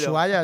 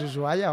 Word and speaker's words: Ushuaia: Ushuaia, es Ushuaia, hombre. – Pero bueno, no hay que meter la J ahí en la H.